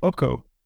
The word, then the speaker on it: OKO: And actually, VoiceOver says it correctly. It's called OKO.